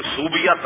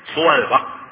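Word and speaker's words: ثویبہ: یہ صوبیہ تلفظ غلط ہے، سویبہ، یہ سویبہ.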